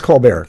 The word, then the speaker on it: Colbert: So, and as a matter of fact, the two of them were on Stephen Colbert's show when he had the comedy, Is that how he actually pronounced his name? – I think it's Colbert.